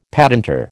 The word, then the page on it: patentor: American English